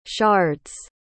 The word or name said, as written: shards